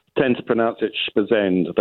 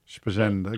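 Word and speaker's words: Esposende: It's spelled Eposende, but the Portuguese tend to pronounce it Spazend. They drop all the vowels at the beginnings and ends of words here. – Spazend.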